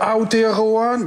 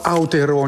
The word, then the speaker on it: Aotearoa: Eh, het is uh, eh, Aotearoa. Het, ze mag dat gewoon op zijn Nederlands uitspreken, zou ik zeggen.